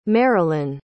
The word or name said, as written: marylin